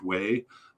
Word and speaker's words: Weah: By far, their most famous player is George Weah, or George Way, I've heard it pronounced.